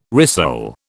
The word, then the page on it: rissole: British English